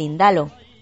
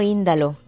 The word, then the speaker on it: Indalo: Famosa es también la figura del Indalo, que no Índalo, que llevo toda la vida pronunciándolo mal.